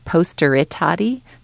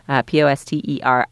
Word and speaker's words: Posteritati: So it's a little confusing what the name is. It's Posteritati. There's like an extra syllable in there. Posteritati.